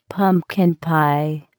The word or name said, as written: pumpkin pie